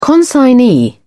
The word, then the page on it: consignee: British English